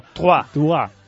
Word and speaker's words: Troyes: Troyes, siempre te digo... – Trois. – Es que hay que saber. – Es que es difícil de decir Troyes. – Ya, por eso yo digo Troyes. Como se escribe, pero hay que saber idiomas.